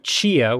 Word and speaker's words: Tchia: check out Chia, which is spelled T-C-H-I-A.